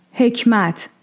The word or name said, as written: Ḥekmat